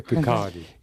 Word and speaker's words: Kirkcaldy: Se pronuncia Kikari.